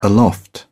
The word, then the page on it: aloft: British English